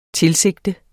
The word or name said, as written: tilsigte